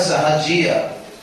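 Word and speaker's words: Sahajiya: Então, um extremo, os sahajas, a palavra sahaja, a palavra sahajia, como se pronuncia? Sahajia, é das palavras sânscritas sahaja.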